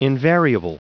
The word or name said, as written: invariable